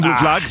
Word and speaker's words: Blue Flag: Recibió la certificación internacional Blue Flag. – Sí. – ¿Lo dije bien? – Sí, muy bien, Blue Flag.